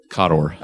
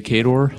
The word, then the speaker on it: KOTOR: And how did you pronounce that again? – Kotor. – Okay, I just want to make sure. Please continue. – You want me to say Kador? – No, absolutely not.